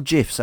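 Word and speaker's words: GIF: Yes, and I know Richard because we share GIFs or GIFs, as I believe it is actually the technical correct pronunciation, on a match day.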